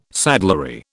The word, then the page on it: saddlery: British English